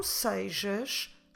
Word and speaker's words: sejas: Standard Portuguese, the one that is spoken in Lisbon area, we tend to say sejas. Now, the spelling doesn't keep up with this pronunciation. Sejas, como se escreve sejas? S-E-J-A-S. Vou repetir. S-E-J-A-S. Although we say sejas, like if there is the letter I in the middle or E in Portuguese, there's no I.